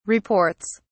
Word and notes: reports: British English

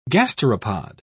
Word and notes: gasteropod: British English

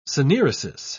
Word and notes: synaeresis: British English

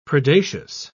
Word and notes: predacious: British English

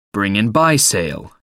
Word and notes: bring and buy sale: American English

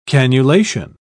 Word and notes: cannulation: British English

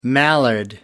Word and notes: mallard: American English

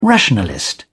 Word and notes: rationalist: British English

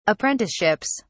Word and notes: apprenticeships: British English

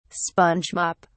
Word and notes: sponge mop: British English

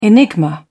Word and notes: enigma: British English

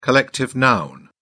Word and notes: collective noun: British English

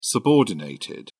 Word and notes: subordinated: British English

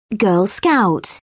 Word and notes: girl scout: British English

